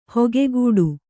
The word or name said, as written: ಹಕ್ಕುಗಟ್ಟು